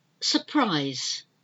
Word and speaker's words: surprise: It's like an A, but it's not, it's finger, F-I-N-G-E-R, finger. We don't pronounce the R. Surprise, S-U-R-P-R-I-S-E. We pronounce the second one, but the first one is almost like it's not there.